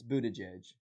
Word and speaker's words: Buttigieg: It's this is the trick to how to pronounce it. I learned this really early on when we were covering this. So say boot. – Boot. – Edge. – Edge. – Edge. – Edge. – Buttigieg.